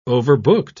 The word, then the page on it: overbooked: British English